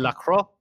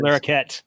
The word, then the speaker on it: Larroquette: John LaCroix as Larroquette.